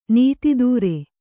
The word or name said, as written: ನೀತಿದೂರೆ